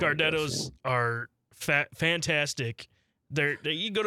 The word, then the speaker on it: Gardetto's: Am I saying that right? Would you say that Giardetto's or Gardetto's? – Gardetto's.